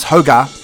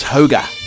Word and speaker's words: hygge: Hygge, pronounced hoga, roughly means cozy contentment, something that brings out warm, fuzzy feelings.